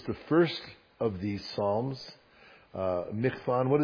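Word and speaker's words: Michtam: We come to our second, and I'm going to try to pronounce it the way my wife taught me in Hebrew a little while ago, Michtan.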